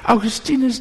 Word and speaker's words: Augustinus: I read in a book of, I don't know how to pronounce, Augustinus, we say in Dutch, Augustinus, that the Lord is putting things you do not like through your food.